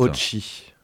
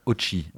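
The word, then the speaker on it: Otxi: Ochi. – Ochi.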